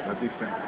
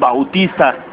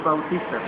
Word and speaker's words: Bautista: Que curiosamente yo me voy enterando que es Bautista. – Uh-huh. Sí, es Bautista. – Sí, los gringos no saben pronunciarlo. – No, sí, por eso es Bautista.